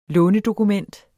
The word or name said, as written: lånedokument